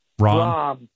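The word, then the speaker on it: Rahm: Rame or Ram? – Rom.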